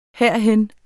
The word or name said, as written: herhen